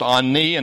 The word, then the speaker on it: ani: It's pronounced ani, and it means I or me, depending on how it's used.